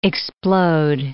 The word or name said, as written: explode